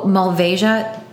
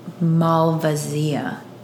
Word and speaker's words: Malvasia: It's pronounced two different ways, as they informed us. – Yes. – Phonetically, those are the two. Oh, Malvasia, that's the American way to pronounce it. – Oh, oops, I'm American. – And the Italian way, or Slovenian way, is the Malvasia.